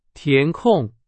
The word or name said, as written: 填空